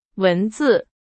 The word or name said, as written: 文字